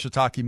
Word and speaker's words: shiitake: Dopey, how do you pronounce shiitake mushroom? Yeah, there you go. Shiitake.